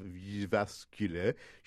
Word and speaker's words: Jyväskylä: Before she went to the University of Jyväskylä, she was in Adelaide, Australia, and she decided that she would do a close look at elevator positioning.